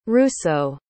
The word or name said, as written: russo